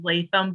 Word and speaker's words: Letham: And then my last name is Latham, but it's spelled L-E-T-H-A-M.